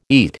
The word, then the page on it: eat: American English